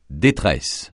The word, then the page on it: détresse: francais